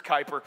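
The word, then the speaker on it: Kuyper: Is it Kuiper? I always said Cooper, but I'm pretty sure it's Kuiper.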